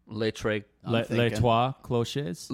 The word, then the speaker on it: Les Trois Clochers: How do you say it? – Think of Ménage à Trois. – Les Trois Clochers.